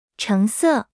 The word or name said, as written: 橙色